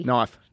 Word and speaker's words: knife: Knife. – Yeah, good job. – I see, that's a silent letter.